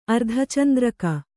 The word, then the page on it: ಅರ್ಧಚಂದ್ರಕ: ardhacandraka